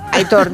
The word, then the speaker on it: Aitor: Es un oyente, sí, que dice, por favor, que Aitor se pronuncia como se lee. – Sí, no. Aitor. – Aitor. La acentuación es en la O.